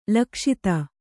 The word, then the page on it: ಲಕ್ಷಿತ: lakṣita